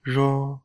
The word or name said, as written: jo